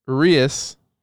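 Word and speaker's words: Urías: So, you're looking at Bueller, Kershaw, Price, Urias, that's how you say his name.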